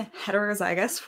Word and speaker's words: heterozygous: So I am heterozygous for the gluten sensitivity gene.